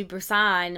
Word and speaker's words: Busan: And then in Train to Busan, am I saying it right? – Yeah.